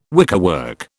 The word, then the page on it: wickerwork: British English